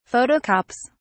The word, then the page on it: photocopies: British English